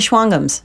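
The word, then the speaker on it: Shawangunk: Well, because it's spelled Schwangunk, but if you lived there, you'd know it was Schwangum.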